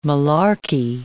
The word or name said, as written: malarkey